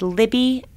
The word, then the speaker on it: Libby: It's Christina, K-R-I-S-T-I-N-A. And my last name is Libby, L-I-B, as in boy, B-Y, .com.